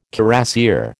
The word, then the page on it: cuirassier: American English